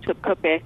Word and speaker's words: Topkapi: The Turkish pronunciation is different, and my Turkish accent's not all that great. It's something like Topkapi. But for Americans, Top Copy, because you think of it like the top copy of a receipt.